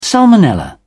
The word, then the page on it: salmonella: British English